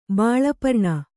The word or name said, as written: ಬಾಳಪರ್ಣ